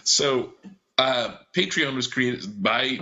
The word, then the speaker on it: Patreon: Joe McDaldno brought to my attention the Patreon website. And I, oh I my god, think I'm pronouncing that right. It's like yeah. patron with an E randomly yes, thrown in there.